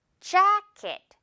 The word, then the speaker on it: jacket: 자, 두 번째로 이것도 우리 J 들어가 있는데 한국식으로 발음을 하다 보면 그냥 제, 재킷 이렇게 발음할 수 있는데요. 제가 아니라 쥬, 요거 생쥐로 시작하면서 jacket, jacket이 되겠죠.